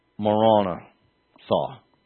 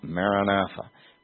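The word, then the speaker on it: Marana tha: Marana saw. And it's not a misprint in the LMT. It's Marana, space, saw, not Maranatha, with that anglicized short A sound.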